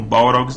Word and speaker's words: Balrog: Balrogs não tem asas. E a pronúncia é Balrog.